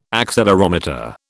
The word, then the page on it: accelerometer: British English